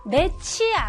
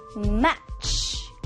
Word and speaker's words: match: 매치 아니에요. 다시 한번 발음. Match. 끝에 취는 아주 시크하게 조금 들어가는 거예요.